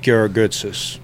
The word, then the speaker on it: Georgoutsos: Pete Georgoutsos.